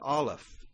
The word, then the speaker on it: Aleph: But Aleph, I believe, is how you would say this. Aleph, accent on that first syllable. It's spelled A-L-E-P-H.